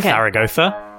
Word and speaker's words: Zaragoza: And I believe the proper pronunciation would be, you know, with the th sound. So it'd be Zaragoza.